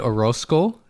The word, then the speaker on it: Orozco: So we have a very exciting guest, David Orozco.